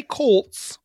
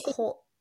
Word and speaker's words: Colts: Now, and now do that as tss at the end instead. – Colts. – That's how you say it. You say Colts.